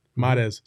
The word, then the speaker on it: Mares: Also, while you're there, why don't you hit that like button like our friend Adam Mayer said. Mares. Sorry, I always say Mayers. Mares. Adam Mares.